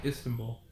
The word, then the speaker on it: Istanbul: Istanbul? – Istanbul.